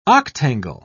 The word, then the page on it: octangle: British English